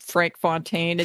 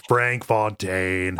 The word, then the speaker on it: Frank Fontaine: Frank Fontaine, and then whatever the ending boss was. Like, I was real overall. – You pronounced that wrong. It's Frank Fontaine.